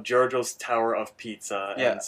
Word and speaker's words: Giorgio's: It has an I in it, which is throwing me off. – It's spelled G-I-O-R-G-I-O's. – I want to say Giorgio's.